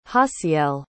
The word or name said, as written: jaciel